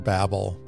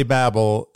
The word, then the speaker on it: Babel: And before we even get too far into it, I feel like I have to talk about the word Babel. Because while most would say Babel, it's also often referred to as Babel, just a different pronunciation, kind of like the Augustine and Augustine situation that I described in a previous episode.